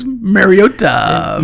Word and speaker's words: Mariota: So it's Mariota.